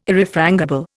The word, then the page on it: Irrefrangible: Indian